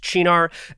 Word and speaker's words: Cynar: And that's C-Y-N-A-R. A lot of people think it's Cynar. No, it's actually pronounced Chinar.